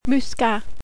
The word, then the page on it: Muscat: French